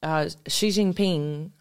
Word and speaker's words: Xi Jinping: Xi Jinping is how you say his name.